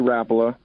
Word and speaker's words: Rapala: Lori Rapala, that's basically how the family pronounced their last name.